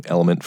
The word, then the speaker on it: LMNT: So, teamed up with our friends over at Element, it's spelled L-M-N-T, because they've changed my experience with drinking water. I've been using Element for the last several months, and it really has made my time drinking water something I look forward to.